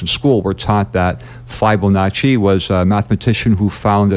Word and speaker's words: Fibonacci: And now I want you to talk a little bit about your research and what you've discovered, because the way, and is it pronounced Fibonacci? – Yes, Fibonacci.